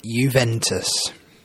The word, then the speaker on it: Juventus: It's even difficult to find teams abroad in the top leagues in Europe other than Juventus. So, yeah. And that's almost a silent J, isn't it? It's a J as an I, nearly. The sort of Roman way, so to speak.